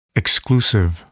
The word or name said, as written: exclusive